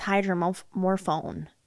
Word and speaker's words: hydromorphone: Because I thought it was pronounced hydromorphine, but it was saying it was hydromorphone, like phone at the end.